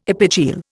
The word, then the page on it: epigeal: Indian